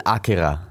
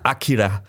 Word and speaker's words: Akira: Das heißt, du betonst immer die Silbe, die du nie im Leben betonen würdest, wenn du Deutsch oder Englisch redest. – Also in diesem Fall Akira. – Akira. Das R ist ganz wichtig.